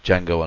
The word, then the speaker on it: Django: and that is Quentin Tarantino's Django Unchained. – I like the way you die, boy. – He is a rambunctious sword, ain't he? – What's your name? – Django. The D is silent.